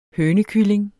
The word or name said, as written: hønekylling